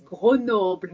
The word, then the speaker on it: Grenoble: Our English listeners, it's Grenoble, not Grenoble.